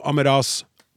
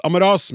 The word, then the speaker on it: Amaras: Uttale jeg er fortrolig med, er lang A, altså Amaras. Bare til orientering, hilsen Kjersti. Så fikk vi en SMS. Det uttales Amaras med lang A mot slutten, uten signaturer nå.